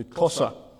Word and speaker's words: Xhosa: The people that I work with in South Africa are mostly from the second largest tribe, and they are known as the Xhosa, and it's spelled X-H-O-S-A. And in every one of those languages, there's a bit of a click.